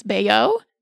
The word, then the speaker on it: Bayeux: But the earliest surviving example of crewel work is the word I can't pronounce, bayeux, B-A-Y-E-U-X, bayeux tapestry, which is not actually a tapestry at all.